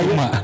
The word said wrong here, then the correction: Kusama: Kusuma apa? – Kusama. – Kusama dulu kalau bacanya Yayoi Kusuma sama kayak gue waktu itu.